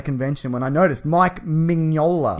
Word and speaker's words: Mignola: Silent G? I think it is, isn't it? – Mignola. – Yeah. – Like filet mignon.